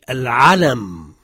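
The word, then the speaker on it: al-alam (العَلَم): Le premier mot, c'est al-alam, c'est le drapeau.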